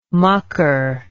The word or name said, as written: mucker